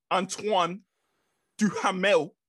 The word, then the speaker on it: Antoine Duhamel: Antoine Duhamel.